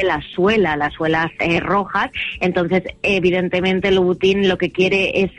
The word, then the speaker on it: Louboutin: Otra cuenta, Louboutin, ¿cómo se dice esto? – Louboutin, sí. Claro, es Christian Louboutin, y bueno, pues la marca lo tiene claro.